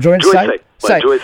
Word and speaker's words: Joël Say: notamment en ce moment avec Joël Say, c'est bien ça ? C'est comme ça qu'on prononce. – Joël Say.